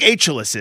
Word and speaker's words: Achille: I think, you know, I don't think Achilles is, though. Like, if he was French and he said Achille, which is how they pronounce it in French.